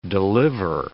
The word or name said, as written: deliver